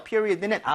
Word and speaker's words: period: period는 앞에 이에다 강세를 좀 많이 주시면 좋아요.